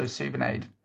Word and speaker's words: Souvenaid: So Suvinaid.